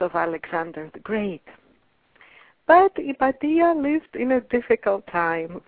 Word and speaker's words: Hypatia: Her name is Hypatia, if you pronounce it the English way, or Hypatia, if you pronounce it the Greek way.